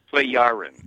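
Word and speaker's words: Plejaren: And it goes on, meaning that he's still in contact with, is it pronounced Plejarans? – Pleyaren. – Pleyaren, okay.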